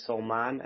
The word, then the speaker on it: Salmaan: I always try to say when you say my name, say Salmaan. And man in Dutch, man, moon, is a good way of trying to remember it. Otherwise, some people start calling me salmon like the fish, which is just as experience has shown me to try to explain it a bit better.